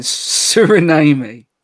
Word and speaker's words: Suriname: Born in Suriname. – Can you Suriname? Give us, where is that? – It's like surname, but with an I. – Yeah. – That is Tsunami. – Suriname.